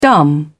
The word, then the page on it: dumb: British English